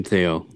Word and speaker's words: Thayil: Kim Thayil offered her only $300,000 for the share.